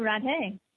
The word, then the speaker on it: Radhe: And how do you pronounce R-A-D-A-T? How do you pronounce that? – Oh, Radhe.